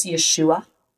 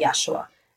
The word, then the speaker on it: Yeshua: So for any potentially Jews that are listening, the pronunciation in Hebrew and Aramaic is Yeshua. But he says that Yeshua is fine as well, just because it's a little bit easier to say.